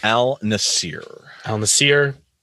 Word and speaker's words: Al Nassr: Al Nassr.